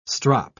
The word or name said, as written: strop